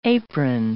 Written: apron

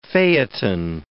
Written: Phaethon

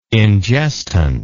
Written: ingestant

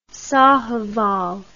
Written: sahiwal

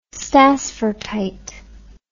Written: stassfurtite

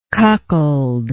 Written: cuckold

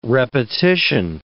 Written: repetition

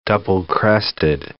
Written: double-crested cormorant

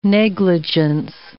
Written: negligence

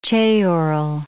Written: ceorl

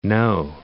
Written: No, Lake

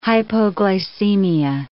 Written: hypoglycemia

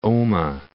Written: Omagh

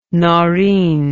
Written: Noreen

